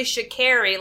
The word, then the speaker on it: Sha'Carri: Now, Hannah, I am going to pump the brakes a little bit just to say that I did look up the specifics on how to pronounce her name, and it's actually Sha'Carri, like our friend in elementary school. – Oh, Carrie, okay. – K-E-R-R-Y.